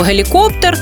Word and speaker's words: гелікоптер: Гелікоптер наголос саме на останньому складі,